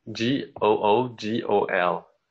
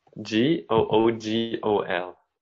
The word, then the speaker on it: googol: Oh, G O O G O L.